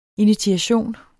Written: INITIATION

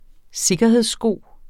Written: sikkerhedssko

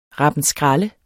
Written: rappenskralde

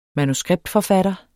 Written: manuskriptforfatter

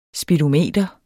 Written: speedometer

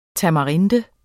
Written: TAMARINDE

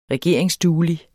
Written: regeringsduelig

